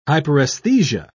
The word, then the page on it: hyperaesthesia: British English